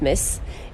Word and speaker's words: MTZ: Nous sommes rue Blondel, où nous pouvons observer l'œuvre de Loïc Lugna, Metz, MTZ, mais qui se prononce Metz.